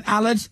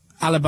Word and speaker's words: Aled: And send a text now saying, Allard. Allard, by the way, is spelled A-L-E-D.